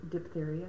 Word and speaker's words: diphtheria: How's that pronounced? How do you pronounce that? – Diphtheria. – Okay.